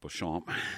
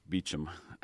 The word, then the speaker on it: Beauchamp: I guess we pronounce it Beauchamp. Living in Florida out here, it always is Beecham. – Right, so right. – I guess the French would say Beauchamp.